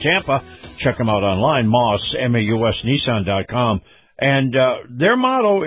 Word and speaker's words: Maus: All righty, this portion of the program brought to you by Moss Nissan. Moss is spelled M-A-U-S, Nissan.